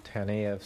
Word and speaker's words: Taneyev: Conus and Sergei Taneyev, I guess Mr. Marston pronounced it Taneyev, something like that, and Leo Conus from December 1893.